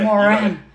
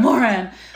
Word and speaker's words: Moran: I want to say that right. Moran. – Yeah. – Moran, Body Smart Fitness.